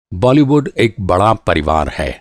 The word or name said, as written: बॉलीवुड